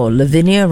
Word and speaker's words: Lavinia: Just so you know, Lavinia rhymes with gardenia.